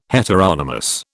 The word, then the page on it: heteronomous: British English